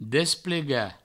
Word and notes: desplegar: Provençal; desplega

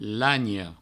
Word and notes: lanha: lagno; Provençal

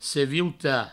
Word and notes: seviutar: sevieuta

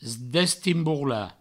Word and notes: destimborlar: destimbourla; Provençal

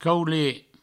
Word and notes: caulet: provençal

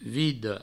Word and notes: vida: vido

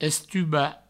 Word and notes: estubar: Provençal; estuba

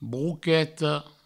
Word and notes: broqueta: brouqueto

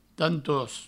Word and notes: tantost: provençal

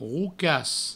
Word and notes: rocas: roucas; Provençal

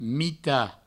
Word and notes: mitat: mita

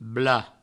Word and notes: blad: Provençal

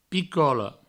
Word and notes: picola: Provençal; picolo

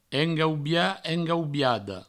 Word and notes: engaubiat: engaubiada; engaubia; engaubiado

